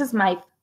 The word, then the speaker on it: Smythe: Según yo es Smythe. Es Smythe, ¿no? Porque tiene la doble, la doble, la Y.